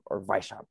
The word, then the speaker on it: Weishaupt: So what I had read about it, there was this man, his name is Adam Weishaupt, or Weishaupt is how they say it in German.